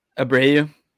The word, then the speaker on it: Abreu: How do you say his name? – Abreu. – Abreu, yeah.